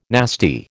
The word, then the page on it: nasty: American English